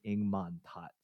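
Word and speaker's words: Ng Man-tat: His name was I think Ing Man Tat, so that's how you say it in Cantonese.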